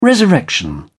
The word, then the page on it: resurrection: British English